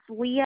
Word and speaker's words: Leigha: It's Leah Georges.